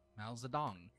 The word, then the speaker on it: Mao Zedong: M-A-O-Z-E-D-O-N-G. Mao Zedong. – Mao Zedong. That's how you say it.